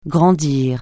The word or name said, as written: grandir